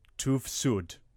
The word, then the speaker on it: TÜV SÜD: It has been approved by this German testing organisation, which I'm now going to pronounce as TÜV SÜD. – Good enough. – Vicky? Is that about right? – That's about right.